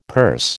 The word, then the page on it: purse: American English